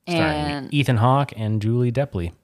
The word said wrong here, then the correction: Delpy: Delpy, Delepi. I can pronounce it however I do please. It's Delpy.